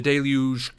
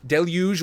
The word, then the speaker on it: deluge: The deluge continues. that was the wackest way. There are two pronunciations for the word deluge. Deluge or deluge.